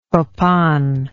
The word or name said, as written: copan